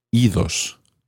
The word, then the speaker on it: idos: So when ir becomes reflexive, irse, meaning to go away, you actually do pronounce the D. Idos, go away. This is probably because ios is quite tricky to say.